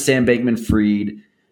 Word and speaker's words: Sam Bankman-Fried: But anyway, point is, when I look at what happened to Sam Bankman-Fried, is that how you say it? – Yeah. – When I look at what happened to Sam Bankman-Fried and who the early backers were, to me, what it looks like is, he was a criminal from the get-go.